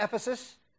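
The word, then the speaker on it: Efes: It's in Turkey, and they actually pronounce it today, Ephes.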